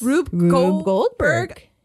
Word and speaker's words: Rube Goldberg: Rube Goldberg.